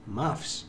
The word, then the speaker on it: Muffs: Pues ahí estaba primero sonando Adolescence y luego The Moves. No sé pronunciarlo, pero bueno, da igual. – Muffs. – El caso, Muffs. – Muffs. – Muy bien, dilo. – Muffs. – Muy bien, dilo, bien.